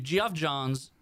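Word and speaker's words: Geoff Johns: If Jeff Johns, I think is actually how you say it. – Geoff. – It's spelled Geoff. I think it's Jeff Johns.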